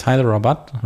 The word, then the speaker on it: Hyderabad: and then another region in India in Hyderabad. How do you pronounce that? – I think it's Hyderabad, right? – Thank you.